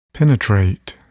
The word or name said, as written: penetrate